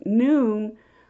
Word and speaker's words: Nun: So since the Ion, Aleph, Nun is pronounced as noon, like the noonday, the letter Hebrew letter Nun in the Aramaic means fish.